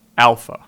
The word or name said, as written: Alpha